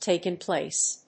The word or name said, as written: taken place